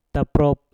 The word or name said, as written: taprot